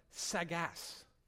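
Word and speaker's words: Sackgasse: John Piper identifies the German word, it's pronounced Sagas.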